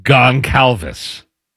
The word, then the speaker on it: Goncalves: The NFL has fined Colts right guard Matt Goncalves. Did I say that right? – Yeah.